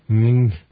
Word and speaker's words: Ng: 15 years later, Hugh Lee Ng, his last name is spelled N-G. – Ng? Ng. Ng. – Yeah. Ng.